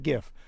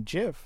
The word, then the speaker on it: GIF: Smucker Company, known in jam circles as Smuckers, comes down on the hard G side of the debate like us. – Mm-hmm. – GIF. – Mm-hmm. – Smucker's latest advertising campaign says the soft G version has already existed for decades in the form of their Jiffy peanut butter brand.